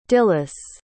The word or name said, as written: dilys